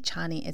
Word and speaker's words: Chani: I see Chani.